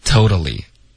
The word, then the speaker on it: totally: 所以 totally, 英文用的很多，口语中很多，所以它的实际发音我们会把第二个特的音浊化为一个的。 Okay, totally.